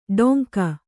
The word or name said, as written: ಡೊಂಕ